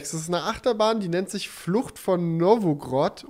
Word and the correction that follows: Novgorod: Novgorod, mhm.